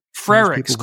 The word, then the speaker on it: Frerichs: Frerichs, Courtney Frerichs. There's no Y in that name, Robert.